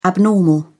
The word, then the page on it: abnormal: British English